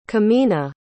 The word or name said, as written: kemena